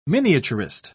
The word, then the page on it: miniaturist: British English